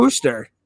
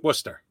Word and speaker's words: Worcester: I thought it was Worcester. – No, it's Worcester. Worcester is how you pronounce it.